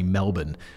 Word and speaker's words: Melbourne: sitting around 13 miles southeast from Melbourne city centre.